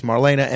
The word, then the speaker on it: Marlaina: Marlaina Schiavo is here. You could follow her on all forms of social media at Marlaina Schiavo. It's Marlaina, MARLAINA, and then Schiavo spelled like Terry Schiavo, SCHIAVO.